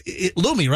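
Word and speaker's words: Lume: It's Lumi. L-U-M-E. L-U-M-E. And it's pronounced Lumi, right? – Yes.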